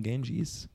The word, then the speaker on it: Ganges: I like the name Ganges. – It's actually the Ganga. – Oh, I'm pronouncing it wrong? – Well, that's the westernized way to say it. Hmm.